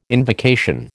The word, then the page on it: invocation: American English